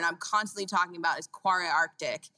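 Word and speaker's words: Kvarøy Arctic: It's Quarry Arctic. I didn't know how to pronounce it for a very long time.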